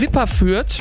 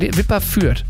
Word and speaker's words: Wipperfürth: Und auch ein Live-Hörer, Luca aus Wipperfürth. Ja, richtig gesagt. Wipperfürth. – Wipperfürth.